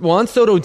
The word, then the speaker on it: Juan Soto: What Juan Soto did, first, am I allowed to say Juan Soto? Am I pronouncing that right? – Yes, because his name is actually Juan. – Juan Soto. – As opposed to the word W-O-N when someone has won multiple games.